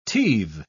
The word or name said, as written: teethe